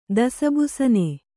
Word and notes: ದಸಬುಸನೆ: kannada

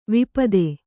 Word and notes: ವಿಪತ್ತಿ: Kannada

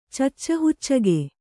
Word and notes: ಚಚ್ಚಹುಚ್ಚಗೆ: kannada

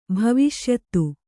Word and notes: ಭವಿಷ್ಯತ್ತು: bhaviṣyattu; kannada

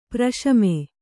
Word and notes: ಪ್ರಶಮೆ: praśame

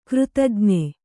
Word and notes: ಕೃತಜ್ಞ: křtaj'na

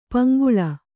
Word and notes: ಪಂಗೋಳಿ: Kannada

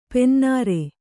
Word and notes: ಪನಿನೀರು: pani nīru

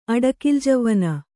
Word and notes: ಅಡಕಿಲ್ಜವ್ವನ: Kannada

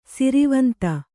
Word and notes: ಸ್ರವಂತಿ: sravanti

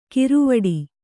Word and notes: ಕಿರುವಡಿ: kiru vaḍi

